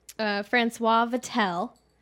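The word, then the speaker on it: François Vatel: So in 1671, Francois Vatel.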